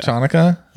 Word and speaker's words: Chanukah: Unless you can pronounce the C and call it Chanukah. – Chanukah.